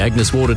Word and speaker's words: Agnes Water: Down to Agnes Waters? – Mate, it's pronounced Agnes Water.